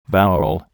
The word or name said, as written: vowel